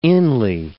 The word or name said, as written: inly